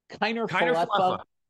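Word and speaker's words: Kiner-Falefa: How do you say that? Falefa? – Kiner Falefa. – Isaiah Kiner Falefa.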